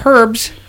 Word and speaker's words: herbs: The Casa Dom Inaccio, the compound, also sells herbs, blessed items. – Herbs. The H is silent.